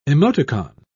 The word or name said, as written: emoticon